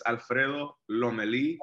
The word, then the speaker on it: Alfredo Lomelí: Alfredo Lomelí, right? – Am I pronouncing it? Lomelí. Yeah, man. Look, feel free to call me Freddy. It's Alfredo Lomelí.